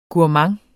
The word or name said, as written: GOURMANDS